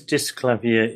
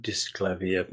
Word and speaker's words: Disklavier: I was slightly puzzled by the pronunciation of Disklavier, thinking it should be pronounced the French way. A bit of Googling proved me wrong, and you right, of course.